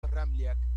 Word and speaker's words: Dralcaj: Es un poco complicado porque tiene tres consonantes juntas y la J se pronuncia como una I. Bien pronunciado el apellido Dralcaj.